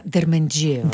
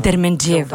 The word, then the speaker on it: Дерменджиева: Да, Дерменджиева. – Дерменджиева. – Да ударим ударението правилно.